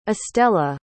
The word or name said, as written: estela